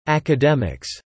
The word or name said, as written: academics